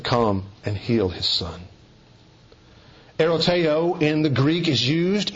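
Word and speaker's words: eroteo (ἐρωτάω): When you go to the Greek, you'll find a word used, a Greek verb that is pronounced eroteo.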